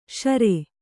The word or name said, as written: ಷುರು